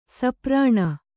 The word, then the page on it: ಸೂಪರಣ: sūparaṇa